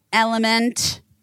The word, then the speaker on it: LMNT: Elementy. – I think it's just element. – I know, but I like LMNT. All right, element. LMNT. – The product is called Element, but the it's spelled LMNT.